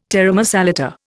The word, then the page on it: taramasalata: Indian